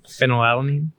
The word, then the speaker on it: phenylalanine: Phenylalanine.